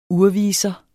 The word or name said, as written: urviser